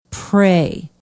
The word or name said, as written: pray